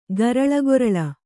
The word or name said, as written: ಗರಳಗೊರಳ